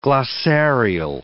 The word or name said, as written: glossarial